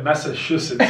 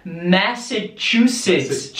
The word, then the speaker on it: Massachusetts: E a última clássica é Massachusetts. – Essa aí é o nome do estado. É bem difícil. Esse estado eu acho um dos mais difíceis de se pronunciar, que é Massachusetts.